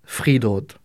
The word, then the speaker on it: friidrott: L'athlétisme, friidrott. – Friidrott.